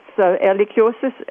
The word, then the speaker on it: Ehrlichiosis: Let me ask you, what changes are, because the new disease, I don't know if I'm going to pronounce this correctly, is Ehrlichiosis. – Yeah, that's Ehrlichiosis is named actually after a German microbiologist who died a long time ago. And so anyhow, there is more than one type of Ehrlichiosis in the U.S., but only one in Minnesota.